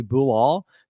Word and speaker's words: Bilal: I found a pronunciation. His first name is actually Bilal.